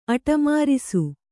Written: ಅಟಮಾರಿಸು

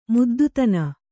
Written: ಮುದ್ದುತನ

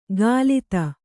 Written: ಕಲಿತ

